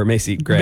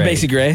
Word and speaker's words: Macy Gray: The first girl kind of sounded like Macy Gray or Macy Gray. – Macy Gray. Yeah.